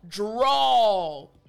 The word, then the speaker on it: drawl: So, drawl. You can tell at the end there's this aw sound, right? So, I want to emphasize the AW portion of the word, aw.